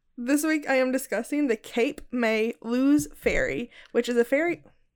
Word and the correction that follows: Lewes: Cape May Lose Ferry. L-E-W-E-S. Lewis? Lewis? – L-E... Wait, spell it again? – L-E-W-E-S. – L-E-W. – Lewis. – Lewis? – Lewis. – Lewis. L-E-W-E-S? – Yeah. – Yeah. – Okay. – Lewis, right?